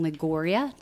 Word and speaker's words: Liguria: There was a report that was produced, I think, last year that said the people that live in Liguria, did I say that properly? – You sure did.